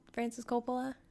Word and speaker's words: Francis Coppola: Francis Coppola.